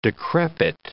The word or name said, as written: decrepit